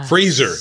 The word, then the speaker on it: Fraser: Fraser, not Fraser.